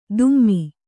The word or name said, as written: ದುಮ್ಮಿ